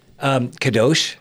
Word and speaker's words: kadosh: Kadosh.